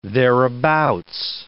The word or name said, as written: thereabout